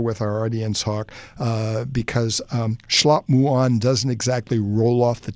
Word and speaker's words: Shlap Muan: We spell it S-H-L-A-P, M-U-A-N, Schlop Muon. And that's the way you say chicken wings in Khmer.